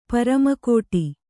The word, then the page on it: ಪೆರ್ಮೆಗಿಡು: permegiḍu